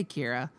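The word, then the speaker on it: Q'ira: Just say Kira. It's spelled like it. – Q-I apostrophe R-A.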